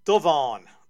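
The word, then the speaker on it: Dubhan: Saint Duvan, that's spelled D-U-B-H-A Father N, and the MVP in the NBA Finals of 2006, who is also the Miami Heat's all-time leading scorer.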